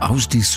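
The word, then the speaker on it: Ásdís: Asdis war das, oder Asdis, so spricht man es, glaube ich, richtig. Schreibt sich Asdis, Asdis gesprochen.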